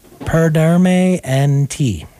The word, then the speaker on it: Perderme en Ti: And then we had the Lola Club with Perderme en Ti. Am I pronouncing that right? – Muy bien, sí.